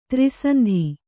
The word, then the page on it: ತ್ರಿಸಂಧಿ: tri sandhi